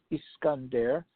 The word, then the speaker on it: Iskander: Many experts said at the time that those missiles bore a strong resemblance to the Russian-designed Iskander, which means Alexander, that's the way they pronounce it in Russian, a short-range nuclear-capable ballistic missile that has been in the Russian arsenal for more than a decade.